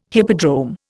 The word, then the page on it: hippodrome: Indian